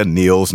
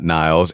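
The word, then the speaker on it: Nils: Yeah, Niels, not Niles.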